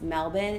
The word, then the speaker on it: Melbourne: Is Melbourne pronounced Melbourne? And she was like, yeah.